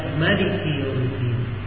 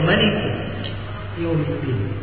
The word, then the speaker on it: Maliki Yawmiddin: Dan datang dalam sebagian qiraat di qiraat Sabah dibaca Maliki Yawmiddin, mimnya dipendekkan, dibacanya Maliki Yawmiddin.